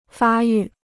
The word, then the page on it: 发育: fā yù